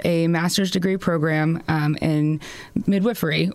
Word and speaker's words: midwifery: How do I say that correctly? – It is midwifery. Yeah, it is the midwife practices midwifery. It's funny, even midwives, before we go to school, a lot of us don't know how to say it properly as well.